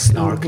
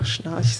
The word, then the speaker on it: Schnarch: Du hast auch auf deiner Website geschrieben, du hast unter oder mit oder bei David, wie spricht man ihn, Schnark gelernt. – Richtig, Snark, in Deutschland sagt man einfach Schnarch.